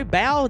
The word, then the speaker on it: Bao: I believe that it's pronounced B A O Bow.